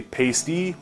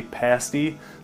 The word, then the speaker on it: pasty: First off, part of the Youper and Flatlander feud is the pronunciation of this word. They say pasty, we say pasty.